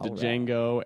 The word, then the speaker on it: Django: named Django with a D. Yep. The D is silent. – The D is silent. – So a couple named Django and Maria Maximoff.